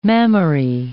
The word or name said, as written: mammary